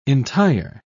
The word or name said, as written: entire